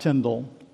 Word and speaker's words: Tyndale: William Tyndale, that great Bible translator, you really need to know something about Tyndale. It's pronounced Tyndale, not Tyndale.